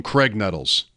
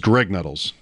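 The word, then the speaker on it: Graig Nettles: Always a tell with fans if they called him Craig Nettles. Because it's Greg Nettles. – I've probably, which is it now? It's Greg Nettles? – Greg Nettles. – Is it spelled like regular Greg, though? I feel like there's... – No, it's spelled like Craig, but with a G instead of a C.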